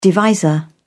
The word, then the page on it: divisor: British English